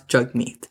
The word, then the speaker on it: Jagmeet: So if you can say Saskatchewan, you can pronounce Jagmeet.